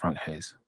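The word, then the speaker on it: Franck Haise: Another manager, a third of that, is the Lens manager, Frank Heis. Heiser? Heis? Frank Heis? I think it's pronounced Frank Heis.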